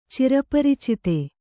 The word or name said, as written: ಚಿರಪರಿಚಿತ